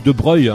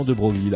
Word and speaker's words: Broglie: Et la famille de Broglie, ou de Breuil, hein, quand on prononce bien, on dit de Breuil, tu vois. Et quand on prononce pas bien, c'est qu'on lit intégralement de Broglie, d'accord, ok.